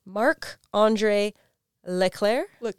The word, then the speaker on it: Leclerc: His name is, can I pronounce it properly? Marc-André Leclerc. It doesn't look like that. That's how you say it.